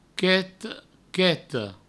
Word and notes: quet, queta: provençal; quet, queto

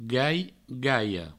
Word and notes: gai, gaia: gai, gaio

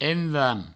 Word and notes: envam: envanc; provençal

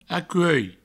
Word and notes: acuelh: acuei

